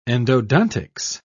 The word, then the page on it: endodontics: British English